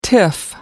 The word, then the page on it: tiff: American English